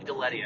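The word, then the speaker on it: Gulitti: Is that how it's pronounced? – It's actually Galletti, actually.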